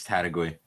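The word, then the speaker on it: Taitague: Is that how you pronounce it? Is that right? – Yeah, I think it's Tadagui.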